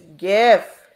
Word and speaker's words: GIF: It's GIF. I don't know why do we have to do this every week? Why? – That's how the creator pronounced it. That is how I will pronounce it.